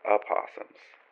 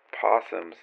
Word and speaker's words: opossum: And that in America, we technically have opossums. And I think possums, if I remember reading correctly, is Australia and New Zealand, I want to say. But in America, you can also pronounce opossum as possum.